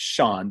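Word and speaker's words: Shawon: Well, it's pronounced Sean, but spelled. – But it's spelled Shawan.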